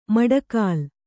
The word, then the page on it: ಮಡಗಾಲ್: maḍagāl